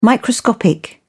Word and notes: microscopic: British English

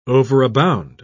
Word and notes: overabound: British English

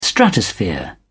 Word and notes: stratosphere: British English

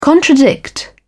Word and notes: contradict: British English

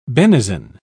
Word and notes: benison: British English

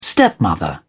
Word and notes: stepmother: British English

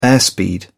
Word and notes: airspeed: British English

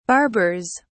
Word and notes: barbers: British English

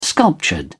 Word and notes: sculptured: British English

